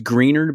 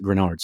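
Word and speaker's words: Greenard: It looks like it's pronounced greener, but I've watched enough tape and it seems like the play-by-play guys always pronounce it Grenard.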